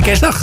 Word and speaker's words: kerstdag: Zeg jij eerste kerstdag of eerste kerstdag? – Nee, het eerste. – Ja? – Ja, de klemtoon op de kerst.